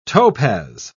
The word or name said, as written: topaz